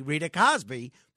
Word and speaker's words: Cosby: And for some reason, I say her name properly, Rita Cosby.